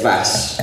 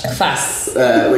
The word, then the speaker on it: Kvass: Kvass. – Kvass.